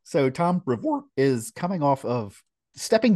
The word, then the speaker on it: Brevoort: So it turns out our dear friend, Tom Brevoort, if I'm pronouncing that correctly. – Brevoort.